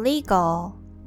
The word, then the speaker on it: legal: And the third one, legal, legal, legal. The E in the first syllable pronounced as the long E, li, li, and the AL pronounced as all, all, legal, legal.